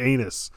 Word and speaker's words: Annis: His name is really Annis. Annis Skuckis. – Johnson has, it's a long A sound.